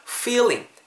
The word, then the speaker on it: feeling: feeling F 사운드 살리셔야 돼요. 윗니 아래입술에 대시고 끌으면서 feeling feeling 좀 길게 발음해 주세요.